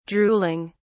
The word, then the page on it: drooling: British English